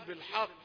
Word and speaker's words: الحق: حاء بالحق نقوله بالحاء بالحق بالحق، يعني فخم الحاء لأنها من حروف الرقة ليست من حروف التفخيم، وغاية ما في الأمر أنها جاء بعدها حرف مفخم فأنت تنطق في فتحة واحدة بالحق، نقول بالحاء بالحق.